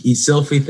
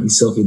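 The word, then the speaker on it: Eszylfie: Esilfie, think of a selfie like you're taking a photo, put an E in front. Esilfie, that's the pronunciation. If you Googled me, you find a lot of stuff. E-S-Z-Y-L-F, like Frank, I-E, Esilfie Taylor.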